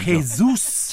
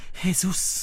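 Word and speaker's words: Jesús: L'accento sulla U, coraggio, coraggio. – Jesus. – Ce la fai. – Jesus. – Poi Lopez. – Dillo tu, Jesus.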